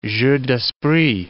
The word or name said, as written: jeu d'esprit